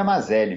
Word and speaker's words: Mazelli: E, e o, o nome é Mazelli mesmo, é, é, é, como se escreve mesmo.